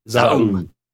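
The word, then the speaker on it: ZA/UM: I just realised I don't know how to pronounce the developer's name. – Zaum.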